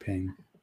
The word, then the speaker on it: Pym: Yeah, Dr. Hank Pym. – Okay. I was making sure I was pronouncing that right. I don't know how else you could pronounce it, but you never know. – Pime, maybe? – Maybe.